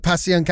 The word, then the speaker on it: Passyunk: Well, what I love is that you're kind of bringing that dive bar atmosphere today to the Passyunk Avenue. Is that how you say it? – Yeah, it's exactly how we say it.